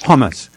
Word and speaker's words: Homs: I think I've been mispronouncing it. I said Homs, but how do you pronounce that? – Homs.